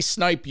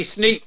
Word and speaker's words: Sneap: Let's touch base on the last 10 years from the, let's pick it up from Call to Arms, the Andy Snipe years. When did Andy come on board? – Andy Sneap, you mean, yeah, not Sneap. Snipe. – Andy Sneap, thank you.